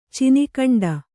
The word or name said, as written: ಚಿನಿಖಂಡ